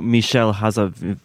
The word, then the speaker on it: Michel Hazanavicius: Oh, Michel Hazavicius.